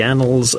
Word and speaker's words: annals: Dan Thirlwell, a new name to the annals of Fly Me to the Moon. And at least I thankfully pronounced annals in the proper way.